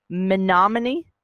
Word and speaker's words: Menominee: They tell me if I'm saying it wrong. Menominee? – Nailed it. – Got it. – Sounds, yay. – Sounds right.